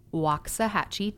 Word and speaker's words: Waxahachie: I said Waxahachie, Texas. I said it right. – That's right. You got to get that correct.